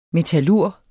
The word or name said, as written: metallurg